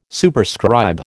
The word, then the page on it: Superscribe: American English